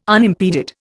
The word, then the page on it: unimpeded: Indian